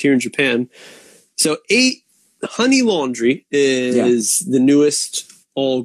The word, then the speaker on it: 8ny: It is spelled 8NY honey. Ha is kind of a sound connecting sound to the word hachi, which means eight. So ha ni laundry. Okay, that's what it is.